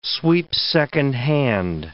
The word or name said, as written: sweep-second hand